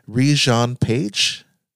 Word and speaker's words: Regé-Jean Page: Regé-Jean Page.